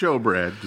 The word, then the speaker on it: shewbread: Yeah, which is pronounced showbread, despite being spelled.